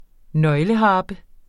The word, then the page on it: nøgleharpe: ˈnʌjləˌhɑːbə